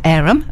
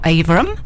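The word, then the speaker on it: Averham: Some of you may also know that it's pronounced Arum and not Averum.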